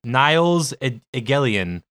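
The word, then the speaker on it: Nils Egelien: It is spelled... hold on a second. N-I-L-S and then E-G-E-L-I-E-N. Like Niles Egelian. Egelian.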